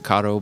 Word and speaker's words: Caddo: Like that was it because we were staying at Caddo Lake, which is everyone would pronounce it Caddo, but like I told my brother-in-law, he's from like East Texas. He's like, we call it Cato, and I'm like, whatever.